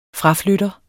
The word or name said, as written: fraflytter